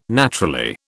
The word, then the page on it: naturally: British English